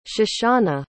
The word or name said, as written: shoshanah